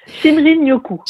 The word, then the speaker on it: Shinrin-yoku: Je voulais revenir justement à votre séjour au Japon et puis la pratique du Shinrin-yoku, ça se prononce comme ça, bain de forêt? – Oui, Shinrin-yoku.